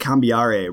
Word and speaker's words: Cambiare: Why don't you tell us a little bit about, I believe it's pronounced Cambiare, right? Is how that's the first book in your series? – Yes. Awesome. It's a musical notation. That's why it's pronounced that way.